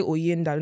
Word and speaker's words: Oyinda: Say Linda. They'll say Linda. I'll say, okay, now remove the L, put OY. Say Oyinda.